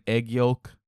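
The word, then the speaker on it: aegyo: it's kind of like egg yolk. Like eggyo. It's A-E-G-Y-O. Eggyo.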